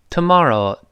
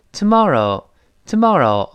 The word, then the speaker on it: tomorrow: 你再体会一下，美国人都成 tomorrow，tomorrow，好读吧？英国人是 tomorrow，tomorrow。